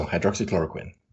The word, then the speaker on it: hydroxychloroquine: Trump had told reporters that he was taking hydroxychloroquine. Is that how it's pronounced? – I believe so, hydroxychloroquine.